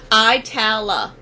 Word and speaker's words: Itala: Yeah, and then before that, uh, I'm not even sure how I'm really pronouncing it right, because I want to say Attila, but it's not that. Atala? – Ita- Italia? – Yeah, maybe Itala? that's it. – From Raleigh, North Carolina, so uh, very cool heavy metal band. Uh, they sent me a, a like and all that stuff, so I wanted to play some of their shit. – Itala. That's Itala. how you pronounce it. Itala.